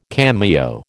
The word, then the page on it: cameo: American English